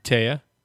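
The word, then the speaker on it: Téa: How do you pronounce her first name? Tia or Taya? – Taya.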